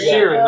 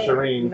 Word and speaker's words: Sheeran: You pronounced his name wrong, so he's probably not going to come anyway. – Yeah, it's Sheeran, not Shireen.